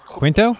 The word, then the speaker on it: Quinto: And you have Zachary Quanto, or Quanto. Oh boy, I should have looked up how you say Quinto. – It. Quinto? Is that how you say it? – Yes.